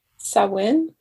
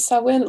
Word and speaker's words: Samhain: samhain i believe is how you pronounce it and like so many other uh,